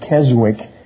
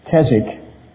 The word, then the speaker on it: Keswick: Sometimes they pronounce it Keswick, but it's Keswick.